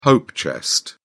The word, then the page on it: hope chest: British English